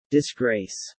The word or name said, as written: disgrace